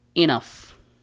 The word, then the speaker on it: enough: دائما حرف الجي والاتش إذا أتى في وسط الكلمة يعني لم ينتهي بها يكتب ولا ينطق، هذا في الغالب. أما لو أتى في نهاية الكلمة في الغالب دائما ينطق بحرف الفاء، مثل كلمة enough أو كلمة calf.